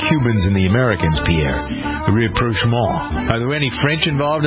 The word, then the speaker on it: rapprochement: You hear me trying to pronounce it? Rapprochement. – It is rapprochement. What is the word again? – Rapprochement. – Rapprochement. – Yes.